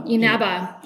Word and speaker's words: Inaba: Inaba, Japanese. Inaba, who is the manager of the Academy at Predator and the sole owner of Kyla Inaba Golf.